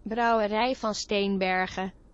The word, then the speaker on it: Brouwerij van Steenbergen: Brouwerij van Steenbergen. – That's in Dutch. – Okay. – That's how you pronounce it in Dutch.